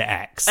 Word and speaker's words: Aix: No, it's A-I-X. Okay. And I did check, and that is apparently you do pronounce it X.